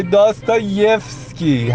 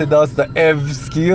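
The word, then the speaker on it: داستا یفسکی (Достоевский): بله بانوی میزبان اسم کتاب نویسنده های داستا یفسکی هر تلفظ دیگه غلط داستا افسکی و داستا یفسکی اینا داستا یفسکی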